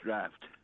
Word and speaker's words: draught: You never heard about the Swedish draft? – No. I was... – How do you pronounce D-R-A-U-G-H-T? – Draft. – Okay, thank you. – Yeah. However, D-R-O-U-G-H-T is drought, because English.